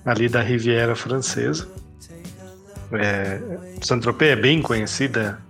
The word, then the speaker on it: Saint-Tropez: É fazer uma correção aí, boi, desculpa aí te corrigir é, a pronúncia, digamos assim, corre correta, ou pelo menos próximo seria algo como Saint-Tropez.